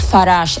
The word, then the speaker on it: Farage: ¿Cómo se pronuncia? Farage, Farage, ¿cómo es? – Farage, es que suena tan francés que es curioso que el hombre sea tan antieuropeísta.